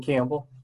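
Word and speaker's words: Kambel: So either way, or at Danny Campbell. My last name is spelled a little bit different. K's in kangaroo, A's in apple, M's in Mary, B's in boy, E-L.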